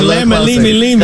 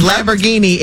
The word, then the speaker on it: Lamborghini: Yeah, closer than lemma limi limi. – Because Lamborghini is 4 syllables. Lamma limi limi. You gave 6, baby.